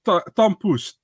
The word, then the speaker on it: tompouce: Tom Poes.